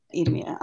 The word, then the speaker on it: Idumea: But the Edomites kind of reemerge from all this more than the Ammonites or Moabites in the Roman period kingdom of, I can never pronounce it right, Idumea, how do you pronounce it? – Idumea.